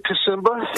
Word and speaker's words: Kusiemba: Is that how I spell your last name? Or pronounce your last name? – We pronounce it Kusiemba.